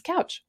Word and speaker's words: Kautsch: Now, Jen Kautsch, now her last name is spelled Kautsch. Yeah, I know. It's like my maiden name. It's kind of different, but hers is simply pronounced Kautsch.